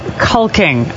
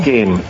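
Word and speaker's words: caulking: Doing some cocking. – It's actually pronounced culking, okay? – Culking. – So don't be gross. – Unless you're Australian, and it's culk.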